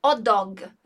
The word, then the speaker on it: hot dog: A gente não vai pronunciar esse som aspirado do R ali, o H não vai ter esse som. Então a gente também emenda o un aqui junto com o dog.